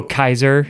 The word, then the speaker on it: Caesar: There is no soft C in Latin. There was no Caesar. – Oh. – It's where we derive the word Kaiser, like the German Kaiser.